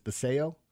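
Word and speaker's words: Basallo: I don't even think I've been saying Basalo, but it's Baseo.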